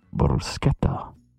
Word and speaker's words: bruschetta: The correct pronunciation is bruschetta. Bruschetta.